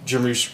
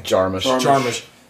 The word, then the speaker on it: Jarmusch: But like this movie feels like it was written after Jim Jarmusch. That's not how you say his name. – Jarmusch.